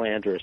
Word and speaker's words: Andrus: Colonel, how do you say his name, Andras? – Colonel Andrus.